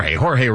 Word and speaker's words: Jorge: Is it George or Jorge? – Jorge Ramos and Paola Ramos podcast talking about Cuba.